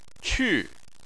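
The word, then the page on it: qu: tone 4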